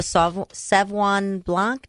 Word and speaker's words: Sauvignon Blanc: It was the Sauvignon Blanc. Did I say that correctly? – Sauvignon Blanc. – Thank you. Not Sauvignon. Hey, I want to say Sauvignon.